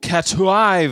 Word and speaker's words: Catuai: It's C-A-T-U-A-I. And, you know, I'm not from Honduras, so I'm pronouncing this the way a guy from North Carolina is going to pronounce it.